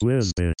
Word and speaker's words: WZBT: WZBT. Pronounced Wizbit.